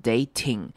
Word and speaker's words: dating: 好， dating 这个字要特别注意的是 t，只要 ing 这个 t 要变成 d 的声音，所以不会念成 dating，这样子念很拗口，要念成 d 的声音 dating because you're dating an actual insane person.